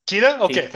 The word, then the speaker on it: Queta: Justin from the Jazz organization, Nemias Queta. Is that his name? – Queta. Okay. Keita. Thank you. Keita. Yeah. I've heard it on broadcast looking at highlights of him as Queta, but I've also heard Queta.